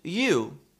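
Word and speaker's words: eu: In Greek, those two letters, and we can pronounce it you, E-U, means good.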